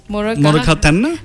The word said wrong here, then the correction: Moragahena: No, Moragahena. – Moragahena, okay.